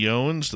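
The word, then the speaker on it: Jones: Jeremy Yones, the guy with two soft J's.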